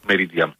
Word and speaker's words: Meridiam: Meridiam ή Meridiam, ποια είναι η σωστή προφορά? – Meridiam. – Meridiam. Γι' αυτό, ωραία.